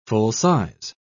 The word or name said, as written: full-size